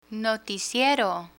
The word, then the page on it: noticiero: Spanish